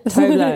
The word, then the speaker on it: Tove Lo: I'm saying it wrong, aren't I? How would you say it? – No, that's right. Yeah. – Tove Lo. Yeah.